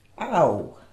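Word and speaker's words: aŭ: Nous avons vu, comment on dit, ou? – Au. – Au, très bien, ça s'écrit A, U chapeau, avec un chapeau qui n'est pas un accent circonflexe.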